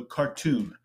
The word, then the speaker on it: Khartoum: Khartoum, the spelling, Khartoum. All right, but it's pronounced like cartoon, like you're watching a Bugs Bunny cartoon, but there's an M at the end, so Khartoum.